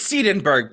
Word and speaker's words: Seidenberg: It's Seidenberg, but you got most of the consonants and pronunciation right.